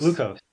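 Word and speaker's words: LEUKOS: He is also the editor-in-chief of Locos. Help me with that. Lucos. How do you say that? Lucos. – Lucos. Yeah, Lucos.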